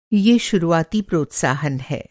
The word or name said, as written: प्रोत्साहन